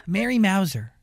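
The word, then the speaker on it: Mary Mouser: Mary Mauser.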